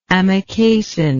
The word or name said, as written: amikacin